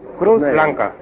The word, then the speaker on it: Cruz Blanca: Y la cerveza marca Cruz Blanca. – How do you pronounce that? – Cruz Blanca.